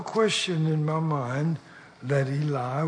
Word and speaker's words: Eli: Eli is the way it's pronounced.